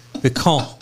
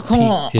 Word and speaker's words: piquant: Piquant. – Spell it. – It has a T on it, but the T is silent. It's piquant.